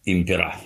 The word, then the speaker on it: impera: Divide et impera. – Да. – То есть разделяй и властвуй. – Impera. На последний слог не может быть ударение в латыни.